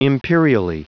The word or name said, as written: imperially